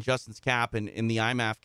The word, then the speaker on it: IMMAF: Tell me how to say that properly, Justin. – We just call it IMAF, the International Mixed Martial Arts Federation.